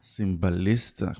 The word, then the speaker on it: סימבליסטה: סימבליסטה, כמו טיל בליסטי.